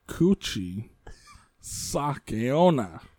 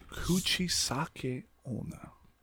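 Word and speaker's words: Kuchisake owner (口裂け女): Say the first one. Pause for a little bit. Say the first syllable. No, two syllables. First two syllables and pause. And then finish it up. – Kuchisake owner.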